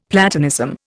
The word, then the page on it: Platonism: Indian